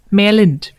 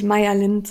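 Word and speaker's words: Mairlynd: Und zwar Shawls, Tücherstricken mit Stil von Melanie Berg, auch bekannt als Merlind, wie wir jetzt wissen, dass es korrekt ausgesprochen ja. wird. Ich befürchte, ich werde trotzdem ständig Meyer Lind sagen.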